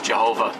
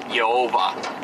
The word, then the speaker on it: Jehovah: Some of the places of the Old Testament in the King James, it uses Jehovah, which is Germanicized letters pronounced wrongly to get Yehovah.